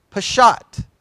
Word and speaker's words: Peshat: I'll spell it for you. P.S. S-H-A-T, Peshat. P-E-S-H-A-T, AT.